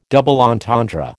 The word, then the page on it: double entendre: American English